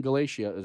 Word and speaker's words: Galatia: So now we're seeing here, Paul is encouraging the church in Galatia.